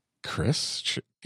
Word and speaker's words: Chris: Like the H in your first name is silent. – Chris? Yeah. – We don't say Kharis.